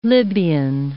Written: Libyan

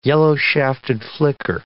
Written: yellow-shafted flicker

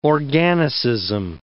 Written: organicism

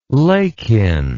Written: Lakin

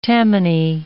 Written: Tammany

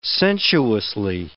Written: sensuously

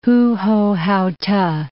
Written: Hu-ho-hao-t'e

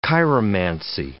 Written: chiromancy

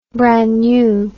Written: brannew

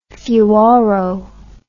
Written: fuoro

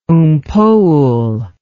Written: Oom Paul

